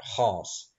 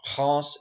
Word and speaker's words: Reims: As Vespasian prepared to march into Gallia to restore order, the Gallic cities held a council at Reims. And yes, for those who are wondering, Reims is spelled R-E-I-M-S, but for some reason is pronounced completely unlike how it's spelled. I don't know why.